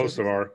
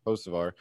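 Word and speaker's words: Hocevar: Row 6, Carson Hochevar. How do you say Hosevar. – It? Hosevar.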